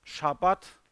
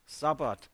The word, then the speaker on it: Shabbat: Es heißt Shabbat und sie sagten Sabbat.